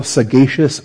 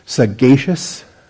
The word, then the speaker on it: sagacious: Under point 13 in the outline, I simply call this six supplemental sagacious sayings. I was mispronouncing sagacious, and somebody had a dictionary and looked it up. Sagacious, with a hard G sound, sagacious.